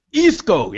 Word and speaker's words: Isco: Isco, yes.